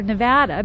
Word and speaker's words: Nevada: East of the Rockies, they'd say Nevada. West of the Rockies, you typically hear Nevada because we know how to say it.